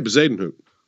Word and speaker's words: Bezuidenhout: 60-1, Christian Bezuidenhout. I think that's how you pronounce his name. – Yeah, Bezuidenhout.